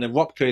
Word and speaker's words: Röpke: Wilhelm Röpke is R-O with an umlaut over it, P-K-E.